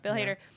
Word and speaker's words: Hader: Am I saying that right? – Yeah. – Bill Hader.